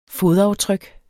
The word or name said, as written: fodaftryk